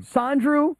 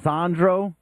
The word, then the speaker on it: Sandro: Sandro Mamukelashvili. – First of all, you got the first name wrong. – The first name is wrong. Now. Oh. Sandro.